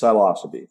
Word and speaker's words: Psilocybe: So the genus is psilocybe. – Got it. Okay. – Yeah, that's how you would pronounce it. Psilocybe.